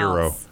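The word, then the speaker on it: gyros: It said it's pronounced Euros, Yee-Ros.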